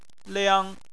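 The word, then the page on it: liang: tone 1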